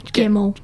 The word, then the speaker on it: Gemmell: Also, for listening on Spotify and stuff, it's Grace, G-R-A-C-E, Gemmel. – Gemmel. – Gemmel. I knew I said that wrong. G-E-M-M-E-L. – L. L. Yeah. G-E-M-M-E-L-L.